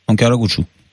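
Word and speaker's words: Ankaragücü: En Karagücü. – En Karagücü. – Gücü. – C'est des U, voilà. Ouais. Et le C se prononce DJ.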